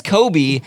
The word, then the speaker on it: Coby: His first name is spelled C-O-B-Y, but it is Kobe.